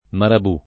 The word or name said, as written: marabù